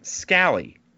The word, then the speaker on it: Scally: What's going on with Utah's DC, Morgan Scally? A is pronounced like apple, i.e. Red Delicious sucks.